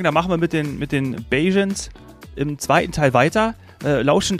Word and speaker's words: Bajans: Ja, also je nachdem, Barbadianer sagen wir natürlich auf Deutsch, und vor Ort auf die Insel nennen sich selber Bajans, also Bajans. – Bajans. – Bajans. Da schreibt man B-A-J-A-N, ja.